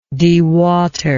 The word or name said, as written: dewaterer